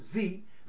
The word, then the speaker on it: Xe: who is now named Blackwater is now named XE, pronounced Z.